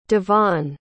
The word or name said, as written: Dyfan